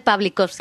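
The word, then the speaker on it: Pawlikowski: Pavel Pavlikovsky. – Pavlikovsky, que es la rehostia, lo cual me alegro muchísimo.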